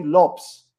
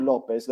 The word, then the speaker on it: Lopes: It was useful, though, because the CBS commentator said that it's actually Ronnie Lopes, not Ronnie Lopez. The Portuguese pronunciation is Lopes.